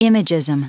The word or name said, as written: imagism